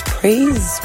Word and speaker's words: Praise: So my name is Praise, and that's spelled as a P R A I S E because people tend to misspell my name.